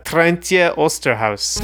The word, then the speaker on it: Trijntje Oosterhuis: I looked up the uh, the pronunciation actually. It's uh, Trijntje Oosterhuis.